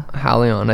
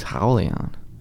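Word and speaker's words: Raulian: Is there an R in there if it's Howleon? – Well, he's Brazilian. R's are H.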